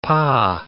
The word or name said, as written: pa